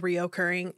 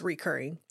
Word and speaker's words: recurring: I said reoccurring. It's recurring.